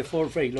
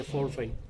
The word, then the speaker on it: forfeit: Sí, se dice forfeit. Lo que pasa es que habitualmente la población dice foul, pero se dice forfeit.